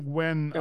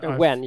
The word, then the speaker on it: Nguyen: Nguyen. – Phil Wen. – Wen.